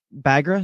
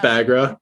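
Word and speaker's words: Baghra: Bagra? Is that how you say her name? – Yeah. – Check me on that. – I think it's Bagra. Yeah, I think it's Bagra.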